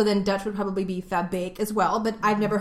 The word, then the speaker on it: Verbeek: It sounded like she was pronouncing her name with a Scottish accent. I'm Lotte Verbeek. – She said Verbeek. – Sorry. – Which to me is Dutch, because the German double E is the A sound.